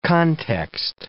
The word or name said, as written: context